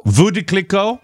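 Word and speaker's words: Veuve Clicquot: How do you pronounce that? Veuve? Clico. – Vue de Clico.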